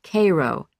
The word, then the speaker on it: Cairo: Cairo, Illinois, he said, explaining that though it was spelled like the city in Egypt, it was pronounced Cairo, the Illinois way.